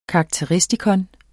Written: karakteristikon

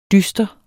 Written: dyster

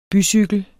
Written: bycykel

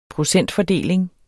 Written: procentfordeling

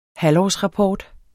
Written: halvårsrapport

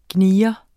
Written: gnier

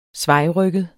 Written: svajrygget

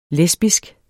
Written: lesbisk

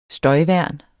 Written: støjværn

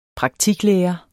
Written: SAVNET

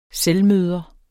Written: selvmøder

